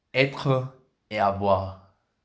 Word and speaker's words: Être et avoir: Te va la pronunciación francesa. Se llama. Être et avoir.